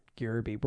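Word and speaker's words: Gerbe: Is it Gerb or Gerby? – I think it's Gerby. – Gerby. We're going to call Nathan Gerby.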